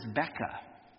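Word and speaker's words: beka (בקע): The Hebrew word is beka. Okay, it kind of rhymes with Rebecca.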